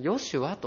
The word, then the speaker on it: ヨシュア: イエスという名前は、これギリシャ語の読み方なんですけれども、旧約聖書のヘブル語、ヘブライ語ではこの名前はですね、ヨシュアと読むんですね。